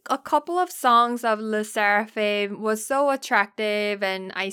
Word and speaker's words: SSERAFIM: SSERAFIM.